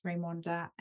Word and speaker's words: Raimonda: So if you go Force4Events or on LinkedIn, I'm under my first name, and that's Raimonda, and that's spelled Raimonda.